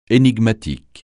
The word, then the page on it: énigmatique: français